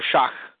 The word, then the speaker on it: Schoch: The video by Robert Schoch, or Schach, as he pronounces it, I guess. It's a German name.